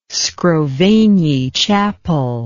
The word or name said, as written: scrovegni chapel